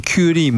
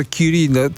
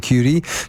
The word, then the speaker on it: Curie: Bardzo ładnie pan Curie mówi, bo ja zauważyłem, że Curie to jest taka najpowszechniejsza wymowa, ale to chyba powinno być jednak tak, jak pan mówi. – Obawiam się, że chyba tak. No, jednak też się staramy właśnie nie tylko promować kolejność Skłodowska-Curie, tylko też wymowę właśnie nie Curie, tylko Curie. To jednak tak.